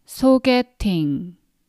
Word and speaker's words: 소개팅: 소개팅. So if I read this word by syllable, it's 소개팅.